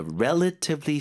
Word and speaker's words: relatively: ETF가 있으면 with ETFs, you can invest in many companies with a relatively small amount. 발음 좀 도와주세요. – Relatively 하셔도 되고요. 네. T를 부드럽게 해서 relatively. – Relatively. – Relatively. – 앞에 힘주고 relatively. 그렇죠? 상대적으로 적은 금액.